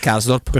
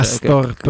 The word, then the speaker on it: Karsdorp: un altro è Nedved sempre per quegli Nedved, altri lì Nedved Nedved Nedved cioè, cercavano queste cose fantasiose eh, un altro è Krasdorp Krasdorp Krasbrot Krastrop oh, non c'è un opinionista de, un una seconda voce di Dazon che sa pronunciare Karsdorp, che poi non è difficilissimo.